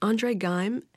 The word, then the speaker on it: André Geim: André Geim and Konstantin Novoslav. Okay. Not so easy. – Right. So you spent some time with the Nobel Prize-winning physicists André Geim and Konstantin Novoslav.